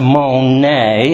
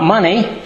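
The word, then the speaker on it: monae (μονή): And the word mansion here is the word monae, and it is pronounced monae, and that gives the English pronunciation there with the accent mark over the E, monae. That's how it is pronounced. And no, it's not money.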